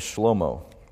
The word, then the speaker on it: Shlomo: And incidentally, and this is kind of just a side note and a free little piece of information, but the Hebrew pronunciation for Solomon is Shlomo.